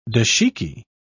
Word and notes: dashiki: British English

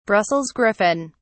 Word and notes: brussels griffon: British English

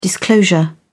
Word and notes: disclosure: British English

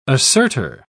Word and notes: assertor: British English

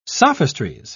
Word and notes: sophistries: British English

